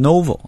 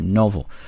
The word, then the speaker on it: novel: 那么这个小说家 The novelist，注意 novel 这个单词在读的时候，中国同学经常读成 novel 啊，觉得这个 no 应该发 no，其实不是，应该发 o 这个音，所以是 novel， novelist。